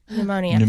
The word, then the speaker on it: pneumonia: Pneumonia. – Ignore the P. – Pneumonia.